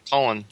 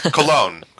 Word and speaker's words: Cologne: Cologne or Colin? – Cologne. Cologne. – Oh, okay. – There's an accent on the second O.